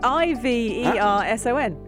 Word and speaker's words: Iverson: Uh, it's I V E R S O N. – Yeah. How do you pronounce that? – Iverson. – But you said IV. Iverson. – Iverson.